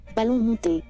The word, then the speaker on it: ballon monté: How, how is that pronounced? Ballon monté. After the siege,